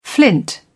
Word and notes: flint: British English

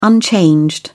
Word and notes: unchanged: British English

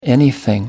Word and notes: anything: American English

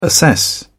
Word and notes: assess: British English

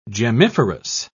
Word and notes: gemmiferous: British English

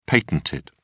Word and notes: patented: British English

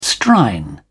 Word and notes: strine: British English

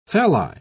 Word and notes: phalli: British English

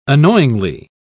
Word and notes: annoyingly: British English

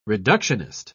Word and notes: reductionist: British English

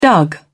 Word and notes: dug: British English

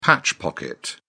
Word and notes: patch pocket: British English

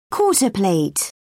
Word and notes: quarter plate: British English

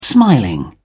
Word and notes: smiling: British English

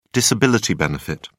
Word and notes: disability benefit: British English

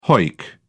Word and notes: hoick: British English

